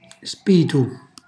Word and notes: spitu: Calabrese

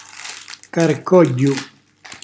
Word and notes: carcogghju: calabrese

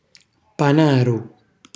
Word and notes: panaru: calabrese

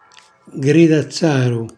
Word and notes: gridazzaru: Calabrese

